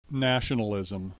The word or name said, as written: nationalism